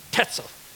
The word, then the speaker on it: Tetzel: Oh, I love that name, Tetzel. Kind of rhymes with pretzel, which I like.